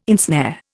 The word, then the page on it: ensnare: Indian